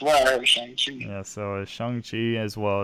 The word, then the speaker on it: Shang-Chi: So it's not Shang. It's not Shang. Apparently we're American, so we said it wrong. So it's Shang-Chi, apparently.